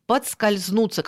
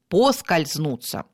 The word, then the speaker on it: поскользнуться: Например, подскользнуться, когда произносят его с приставкой «под». С приставкой «под» мы не говорим и не пишем. Есть слово только с приставкой «по», «поскользнуться». У словарного «поскользнуться», запомните, пожалуйста, приставки «под» не существует.